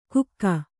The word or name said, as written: ಗುಕ್ಕ